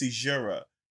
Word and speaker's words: caesura: Today's word of the week is seizura and it's a noun and it's spelled C A E S U R A.